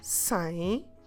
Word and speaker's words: sem: so maybe you should say sem leite. Don't forget when you pronounce sem to leave your mouth open in the end.